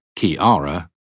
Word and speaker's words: ceare: Ceare, C E A R E, pronounced Kiara, was the Old English word for care and sorrow, emotions that have an annoying habit of striking during the uht.